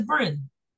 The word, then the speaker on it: Bryn: Yeah, it's pronounced Brian. Like a girl, Brian. Bry, and then Oh no, that's Bryn. – it's Bryn. Oh, it's Bryn. Oh, my bad.